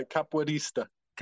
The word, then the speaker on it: capoeirista: Capoista? – You got a capoeirista.